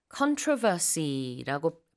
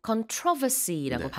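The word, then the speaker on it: controversy: 이 논란, controversy라고 발음하기도 하고 controversy라고 네. 발음하기도 합니다. 강세가 다른데요. 둘 다 가능하고 논란이 많은 국민투표였어요.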